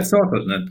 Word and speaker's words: Sortledton: Yeah, Sortledton. It comes from being sorted. Sorted. And it's basically a simpleton idea, you know. It's very simple. It's and that's the combination, Sortledton, in the end.